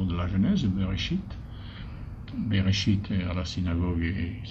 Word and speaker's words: Bereshit: Mais Bereshit ne peut être prononcé que si on met des points voyelles qui le font prononcer Bereshit.